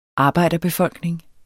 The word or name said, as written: arbejderbefolkning